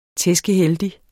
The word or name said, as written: tæskeheldig